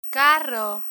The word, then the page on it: carro: spanish